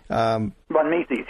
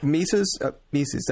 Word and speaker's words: von Mises: Von Mises, yes. – Mises, thank you.